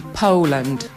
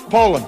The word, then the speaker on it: Poland: To właśnie w związku z tym, że my wymawiamy to słowo niepoprawnie, także jest podobne do Holandii. No i pokazałam właśnie tę poprawną wymowę, czyli Poland przez O. – Distinguished guests, people of Poland. Poland.